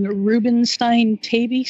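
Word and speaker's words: Rubinstein-Taybi: And you mentioned on your website that your daughter Emma has called, and I know I'm going to say this wrong, Rubenstein-Tabie syndrome. – You got it right.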